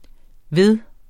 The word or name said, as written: ved